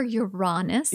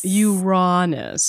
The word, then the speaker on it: Uranus: But yeah, it's pronounced either way. I prefer Uranus. – Uranus.